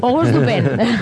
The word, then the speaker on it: Auguste Dupin: ¿lo he pronunciado bien o cómo? – Auguste Dupin.